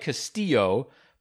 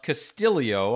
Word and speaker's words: Castillo: It looks like Castillo, but he has told me that it's pronounced Castillo, almost like there's an I between the L and the O.